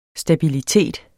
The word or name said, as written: stabilitet